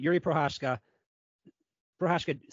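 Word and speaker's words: Jiří Procházka: Jiří Prochazka submitted Glover Teixeira. Yes, I said that right.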